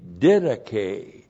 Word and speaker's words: didache: which is in accordance with the didache. That's a great word, didache. D-I-D-A-C-H, long E.